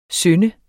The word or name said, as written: SYNDET